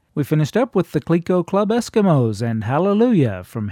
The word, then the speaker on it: Clicquot: Clico is spelled C-L-I-C-Q-U-O-T and pronounced Clico, and was the fictional name of the Eskimo boy that was pictured on the bottle and in the company's advertising.